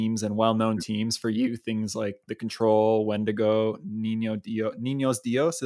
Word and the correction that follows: Niño Dios: Am I saying that right? – Niño Dios.